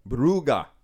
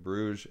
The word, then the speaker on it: Brugge: Group B, we have Club Brugge, which is the proper pronunciation of Bruges, as I've only recently learned.